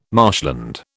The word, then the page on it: marshland: British English